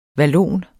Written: vallon

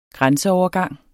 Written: grænseovergang

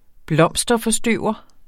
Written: blomsterforstøver

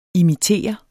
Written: imitere

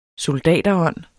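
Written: soldaterånd

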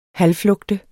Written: halvflugte

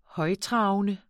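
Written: højttravende

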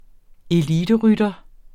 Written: eliterytter

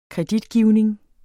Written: kreditgivning

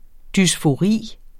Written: dysfori

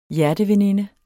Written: hjerteveninde

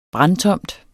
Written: brandtomt

